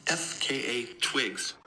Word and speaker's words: FKA Twigs: Pronounced FKA Twigs.